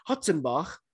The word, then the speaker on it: Hartzenberg: Anyway, around the 57-minute mark, the Griquas fans finally had something to cheer about as a guy whose last name is spelled Hartzenberg, H-A-R-T-Z-E-N-B-E-R-G, which it turns out is actually pronounced Hutzenbach.